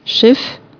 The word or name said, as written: nav